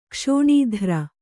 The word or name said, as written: ಕ್ಷೋಣೀಧ್ರ